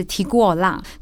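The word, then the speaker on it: 提 过 浪 (תיקון עולם): 对，修复世界观。那那它的发音就希伯来文是提过浪。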